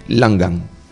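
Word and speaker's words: Langhan: Métanse ustedes, se escribe Lang, G-H-A-N, Lang terminado en G, Lang terminado en G, y después ponen una H, una A y una N, Langan. Mírenlo, está al alcance de cualquiera.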